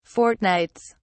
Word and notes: fortnights: British English

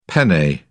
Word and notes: penne: British English

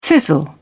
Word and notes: fizzle out: British English

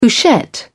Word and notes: couchette: British English

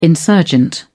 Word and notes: insurgent: British English

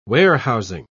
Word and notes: warehousing: British English

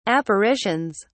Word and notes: apparitions: British English

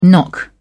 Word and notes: knock up: British English

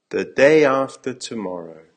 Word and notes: the day after tomorrow: American English

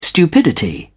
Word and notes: stupidity: British English

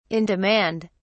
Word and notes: in demand: British English

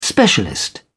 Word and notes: specialist: British English

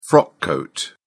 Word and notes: frock coat: British English

